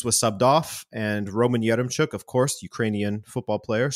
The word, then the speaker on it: Yaremchuk: Benfica's Yaremchuk. Did I say it right? Because this one's yours. – You got it.